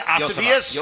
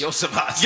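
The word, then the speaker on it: Iosivas: Yeah, I was going to ask you, how do you pronounce his name? Yosivas. – Yosivas. – Yosivas.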